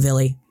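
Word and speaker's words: viili: So we actually just got this yogurt starter that I'm really excited about called Villi, V-I-I-L-I. I call it Villi. That's the American pronunciation of a Scandinavian word. I have no idea if that's correct.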